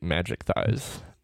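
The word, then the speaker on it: Majikthise: I feel like some of it's literally, at least by the narrator of the book, it's literally Magic Thighs. – Yeah. – Yeah. – He says Magic Thighs.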